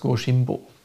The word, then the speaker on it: Goshimbo: Und wenn auf ein N äh, ein H kommt, dann wird daraus in der Aussprache ein B. Ja, und deswegen heißt das Goshimbo.